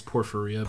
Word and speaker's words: porphyria: Like, for example, I'm probably going to mispronounce this, porphyria. – I believe it's porphyria. – Porphyria.